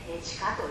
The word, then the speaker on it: 和: 地下と読む